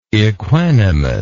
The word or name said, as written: equanimous